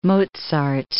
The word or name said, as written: Mozart